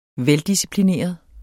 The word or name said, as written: veldisciplineret